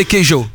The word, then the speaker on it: queijo: Donc je connaissais les, alors je vais très mal le prononcer, mais les pais, les petits pains, les petits... – Les pains de queijo. – Les pains de queijo, ah vous le dites mieux que moi.